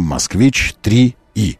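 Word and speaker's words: Москвич 3е: С одной стороны, отечественный электромобиль под названием «Москвич-3И». Пишется «Е», читается «И», я думаю.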